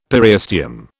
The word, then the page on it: periosteum: British English